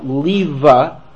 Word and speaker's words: Leva: Leva is an old German term for Leib. It's not in use anymore, because Leib is, of course, the Yiddish for lion, Yehuda Leib, right? So it was Yehuda Leva was the older term. It's not a common pronunciation anymore.